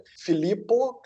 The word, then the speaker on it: Filippo: Filippo, que se escreve F-I-L-I-P-P-O, porque eu sei que meu nome é meio estranho mesmo.